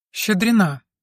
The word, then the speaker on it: Щедрина: «Ольга Щедрина», — проговорил Генри, указывая на стул. «Щедрина», — поправила девушка, присаживаясь. «Это русская фамилия, ударение на последнем слоге.